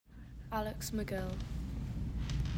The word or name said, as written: Alex McGill